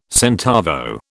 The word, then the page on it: centavo: British English